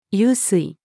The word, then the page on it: 湧水: female